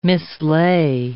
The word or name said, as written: mislay